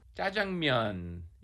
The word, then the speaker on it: Jajangmyeon: Jajangmyeon. Yeah, jajang is the black bean sauce, and myeon just means noodles, myeon.